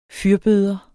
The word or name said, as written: fyrbøder